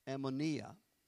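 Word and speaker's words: anomia: Let me spell that for you. It's A-N-O-M-I-A. Ammonia.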